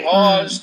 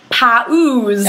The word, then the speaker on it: pause: I think this is difficult for a lot of English learners, because when you have the two different vowels, like the P-A-U-S-E, the tendency is to want to pronounce each one separately, like pa-oos, right? I've heard this before anyway. I'm not just making things up. – I've heard it pronounced pows.